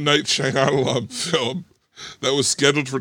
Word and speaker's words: Shyamalan: Shyamalan is, I think, how you say it.